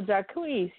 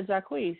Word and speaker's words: Jacquees: I think his name is spelled Jacquees, whatever. – Oh, Jacquees. Yeah, Jacquees. Yeah.